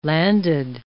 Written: landed

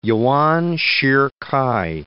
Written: Yuan Shih-k'ai

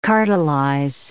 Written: cartelize

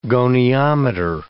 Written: goniometer

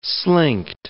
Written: slinked

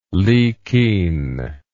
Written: likin

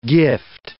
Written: gift

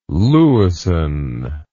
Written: Lewisohn

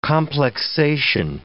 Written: complexation

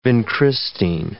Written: vincristine

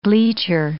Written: bleacher

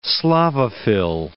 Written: Slavophil

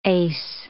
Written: ace

